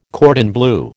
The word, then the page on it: cordon bleu: American English